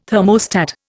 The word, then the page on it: thermostat: Indian